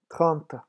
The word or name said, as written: trente